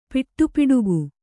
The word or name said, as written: ಪಿಟ್ಟುಪಿಡುಗು